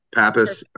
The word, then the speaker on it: Pappas: Yeah, Pappas. But yeah, you got Clement right, which is more than I can say for 99% of the people I meet.